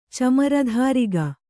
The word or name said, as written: ಚಮರಧಾರಿಗ